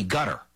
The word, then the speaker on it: Qatar: And what's noteworthy here is the agreement was reportedly brokered by Qatar, or as it is pronounced in D.C., gutter.